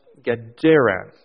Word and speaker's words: Gadara: And He's come here to a city of, pronounced Gadara.